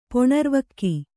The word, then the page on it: ಪೊಣರ್ವಕ್ಕಿ: Kannada